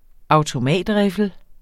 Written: RIFLEN

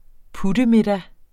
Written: puttemiddag

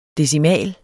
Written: DECIMALER

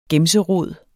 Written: gemserod

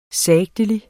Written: sagtelig

